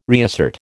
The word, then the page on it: reassert: American English